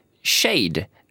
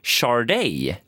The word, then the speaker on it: Sade: Med artisten som det stod att hon skulle uttalas Shade. S-A-D-E. Men då skrev Åsa, som är en av våra lyssnare, att det uttalas ungefär Charday. – Ja, just det. – S-C-H-A-R-D-E-J.